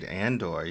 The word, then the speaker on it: Andor: And now Andor, am I saying it properly? – You are indeed, Andor, yeah.